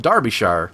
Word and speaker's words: Derbyshire: He says uh, that he's from, now I'm going to pronounce this correctly, Derbyshire, uh, and it's and it's it's spelt Derbyshire, but he's it's pronounced Derbyshire, uh, and not like the demolition derby.